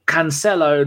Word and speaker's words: Cancelo: By the way, it's Cancelo, not Calcio.